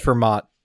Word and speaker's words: Fermat: It's pronounced Fermat.